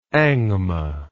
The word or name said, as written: angma